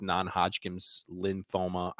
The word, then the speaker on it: non-Hodgkin's lymphoma: And his battle with non-Hodgkin's lymphoma. I don't think I say it right every time, but... – No, you literally said it correctly.